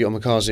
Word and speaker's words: omakase: Is it omakase? – Omakase.